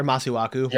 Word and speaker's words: Masuaku: So off of Liverpool for a moment, I mentioned this earlier, but did either of you guys see Masuoku's goal against Chelsea? Or Masuaku, my bad on the pronunciation.